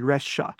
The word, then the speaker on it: Rexha: She's often called B-B-B-E-B-E-R-E-X-A-H-A of Albanian background. That name is actually Resha. So that X is like pronounced like the sh in Xi Jinping or the sh in Xanana Guzmão that you can find in Chinese or in Portuguese.